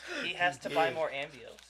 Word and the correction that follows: Amiibo: Yeah. – Amiibos? – Amiibos. That's what he meant to say.